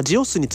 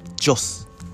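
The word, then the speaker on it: Gios: のイメージカラーというのは違う色だったんですっていう話、えー、これ皆さんご存知でしたでしょうかはいでまあジオスについて説明していきますと、えー、ジオスですね 1948 年で、えー、読み方ですねジオスって日本語では読みますけれどもまあ、イタリア語的な発音するとジョス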